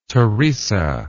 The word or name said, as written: theresa